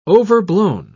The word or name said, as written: overblown